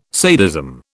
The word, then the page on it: sadism: British English